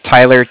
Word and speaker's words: Tyler: Then my name is Tyler, T-Y-L-E-R. – Mm-hmm. – And I'm calling with Cardiff. It's spelled C-A-R-D-I-F-F.